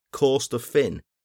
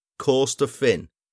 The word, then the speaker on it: Corstorphine: Corstorphine Hill when it's Corstorphine. Must stress, Corstorphine. I actually got a scathing review from someone saying that it had completely ruined the show.